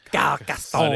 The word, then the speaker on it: Carcassonne: That's how you say it. – Carcassonne.